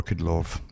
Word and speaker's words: Kudlow: You can either pronounce it Kudlow or Kudlow, doesn't matter, and Stephen Moore.